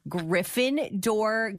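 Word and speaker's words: Griffin Dohr: Griffin spelt like you would spell the name Griffin, but his last name is D-O-H-R. So Griffin Door, spelt different, but literally pronounced like the houses in Harry Potter.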